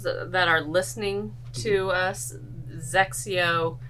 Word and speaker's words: XXIO: XXIO and pronounced Zexio. I always say sexy with a Z, Zexio.